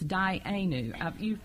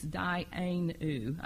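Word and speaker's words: dayenu: And I think I pronounced that wrong. It's day aim. – Anu. – It's dianu. When you get that from me, because I say it wrong, I'm sorry. – And when you see how it's spelled, so it's dianu.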